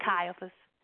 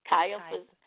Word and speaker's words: Caiaphas: Then let they, Jesus, from, I pronounce that, Caiaphas. – Caiaphas, unto the hall of judgment, and it was early.